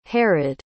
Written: Herod

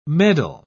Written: medal

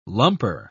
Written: lumper

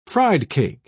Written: friedcake

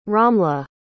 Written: ramla

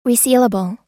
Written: resealable

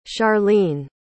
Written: charline

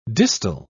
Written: distal

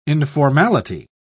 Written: informality

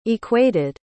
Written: equated

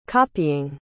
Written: copying

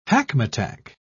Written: hackmatack

